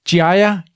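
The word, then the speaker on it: Ghiaia: Uh, faktisk, jeg ved også, du nævner nogen, uh, som hedder, og jeg, jeg siger det sikkert ikke rigtigt, Giaia, Giaia Explorers det øh, er så flot udtalt.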